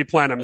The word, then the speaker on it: plenum: But of course, it should be plenum. So thank you for that comment. And we will go with plenum and we will ignore the incorrect AI pronunciations.